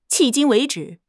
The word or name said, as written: 迄今为止